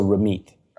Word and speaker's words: Ramit: Why is the T pronounced like a TH and the TH pronounced like a T? – I'll tell you two things about my name. First of all, in Punjabi, the way it's written, it's TH. So Ramit.